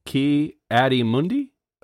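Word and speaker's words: Ki-Adi-Mundi: Ki Adi Mundi?" – That's right.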